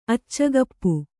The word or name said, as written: ಅಚ್ಚಕಪ್ಪು